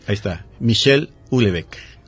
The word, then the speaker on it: Houellebecq: Houellebecq. – Ajá. Se escribe H-O-U, ¿no? Uh-huh. H-E, doble L, ajá. E-B-E-Q, Houellebecq.